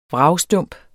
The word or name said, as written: vragstump